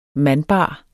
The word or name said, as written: MANDBARE